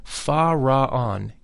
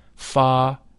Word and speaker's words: Fa-Ra-On: The whole mantram, Fa-Ra-On, is divided into three syllables, thus, Fa-Ra-On. The Fa is that of the musical scale. The Ra is a deep sound. It should be vocalized with a rolling R, Ra.